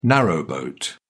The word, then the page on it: narrowboat: British English